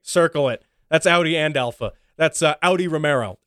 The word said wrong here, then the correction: Romeo: Romeo. There we go. Showing my Americanness there. Europeans always criticize us for mispronouncing Alfa Romeo. Yeah, it's Romeo. If you say Romero, who the hell?